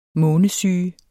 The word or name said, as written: månesyge